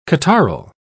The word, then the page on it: catarrhal: British English